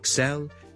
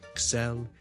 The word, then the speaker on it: excel: To excel at something，即系做某一件事呢做到非常之卓越，好优异嘅，胜过其他人嘅，我哋叫做 to excel at something。个重音呢其实呢佢系应该放喺 the second syllable 嘅。 So, excel, excel, excel。